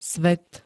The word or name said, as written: svet